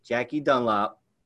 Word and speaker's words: Jackie Dunlap: I'm your host, and today we have my very good friend, Jackie Dunlap of Next Generation Home Loans.